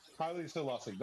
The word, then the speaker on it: Haile Selassie: Haile Selassie. Is that how you say his name? – Haile Selassie.